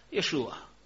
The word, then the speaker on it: Jeszua: Imię Jozue Izraelczycy wymawiali dokładnie tak samo, jak imię Pana Jezusa. Jeszua.